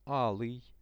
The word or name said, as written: alyj